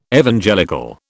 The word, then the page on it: Evangelical: British English